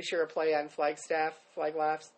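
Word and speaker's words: Flag Laffs: There is a Facebook page called Flag Laughs, and it's spelled FLAGLAFFS, which looks like Flagstaff, but it's Flag Laughs.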